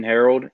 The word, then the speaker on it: Herald: Yeah, so you can follow me on Twitter at Jordan Harald, and my last name is spelled H-E-R-A-L-D, and then 5 at the end of it.